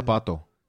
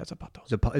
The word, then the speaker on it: zapato: How do you say shoe in Spanish? Zapatilla? – Zapato. – Yeah, Zapato.